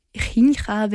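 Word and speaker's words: Jinjer: Ähm, wie gesagt, die Band heißt Ginger, also Jincha, wenn man es äh, spanisch aussprechen würde. Also J ganz vorne, J in der Mitte.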